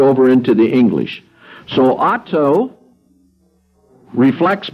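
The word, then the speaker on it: auto (αὐτό): The neuter for it in Greek is pronounced auto, from which we get the English word auto, or self.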